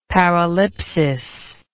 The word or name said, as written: paralipsis